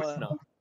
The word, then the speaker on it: Werner: I pronounce it as a V. Do you go for the W? – You are, yeah, because I'm Werner.